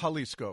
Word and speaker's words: Xalisco: It's Jalisco. – Jalisco, sorry. – Pronounced with an H.